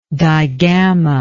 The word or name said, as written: digammated